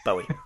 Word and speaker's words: Bowie: No, you know, you always say it wrong. It's Bowie.